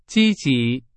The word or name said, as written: 积极